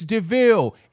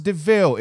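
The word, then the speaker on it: de Vil: So when he pulled up and was like, it's DeVille. It's spelled like devil, but pronounced DeVille.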